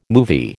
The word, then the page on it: movie: American English